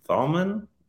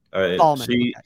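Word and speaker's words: Thalmann: Let's move on to how do you pronounce this properly? I'm probably going to skewer it, but Gael Talman. – I think it's Thalman. – Thalman.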